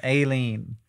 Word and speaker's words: Aileen: the stress is on the A and the E is silent. Aileen.